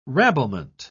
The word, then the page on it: rabblement: British English